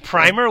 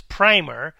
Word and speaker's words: Primer: Oh, you mean Primer? Why would you pronounce it? Because I was saying Primer before, before we started the podcast, but. That's actually just, a little textbook for elementary kids. So I guess it's Primer, which you haven't seen yet, but it's a good time travel movie.